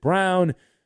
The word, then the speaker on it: Braun: Even some guy who played at Kansas named Christian Brown, although it looks like his name is spelled Braun, but the family pronounces it Brown.